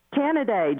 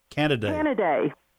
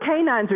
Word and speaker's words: Canidae: Canidae. – That's the species name, C-A-N-I-D-A-E. It's not canine, but canines are dog specific.